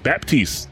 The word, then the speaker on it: Baptiste: Actually, yeah, we'll be recording the day Batiste goes live, or Baptiste goes live. Sorry, I said his name wrong. – The P is not silent.